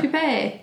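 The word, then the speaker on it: toupée: Was it Toupee? How do you say it? Toupee? – Toupee is correct, yeah. – Toupee.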